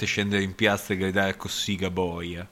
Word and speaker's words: Cossiga: Poi Cossiga si pronuncia Cossiga, ricordati sempre che...